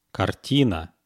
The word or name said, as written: kartina